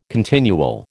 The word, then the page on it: continual: American English